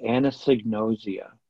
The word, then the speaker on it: anosognosia: It's called anosognosia.